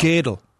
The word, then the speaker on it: Gödel: How do you say it? – Gödel? I think it's Gödel, I guess. I thought it was Gödel. – Gödel. That's how you actually pronounce it. Gödel.